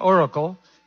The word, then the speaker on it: Oracle: El de Oracle, lo que se pronuncia Oracle,